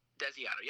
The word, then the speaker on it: Desiato: Desiato, yep. Desiato.